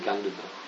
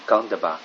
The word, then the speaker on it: Gandhabba: G-A-N-D-H-A-B-B-A. – Yeah, Gandhava. – Gandhava.